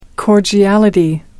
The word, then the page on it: cordiality: American English